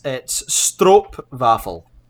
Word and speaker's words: stroopwafel: Yeah, so it's not actually pronounced Stroopwafel. It's Stroopwafel.